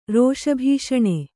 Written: ರೋಷಭೀಷಣ

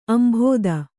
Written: ಅಂಬುದ

